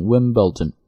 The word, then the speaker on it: Wimbledon: 温布尔登这个词在读的时候要把它读成 Wimbledon， Wimbledon，其实不是 Wimbledon 而是 Wimbledon 啊，这个 L 音要稍微出来一点才好听，